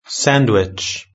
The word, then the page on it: sandwich: British English